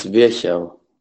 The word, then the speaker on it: Virchow: Well, we've always called him Virchow, but I'm going to play in now a colleague of mine from Germany who corrected my pronunciation. – It's Virchow, which is Rudolf Virchow, father of German pathology.